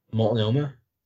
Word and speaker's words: Multnomah: It takes you out to Multnomah.